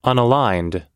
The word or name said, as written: unaligned